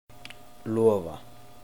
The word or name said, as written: Lofa